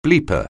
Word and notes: bleeper: anglais

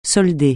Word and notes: solder: francais

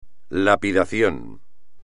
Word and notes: lapidación: espagnol

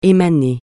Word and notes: émaner: français

